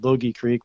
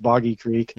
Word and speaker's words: Boggy Creek: I always want to call it Bogey Creek, but it's actually pronounced Boggy Creek.